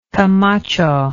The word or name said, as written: camacho